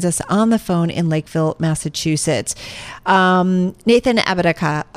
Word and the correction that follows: Apodaca: I don't think I'm saying it right. Apodaca. Thank you. Apodaca.